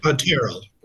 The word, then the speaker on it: Terrell: Terrell or Terrell? How should I say that, Brian? – Terrell. – Terrell, okay.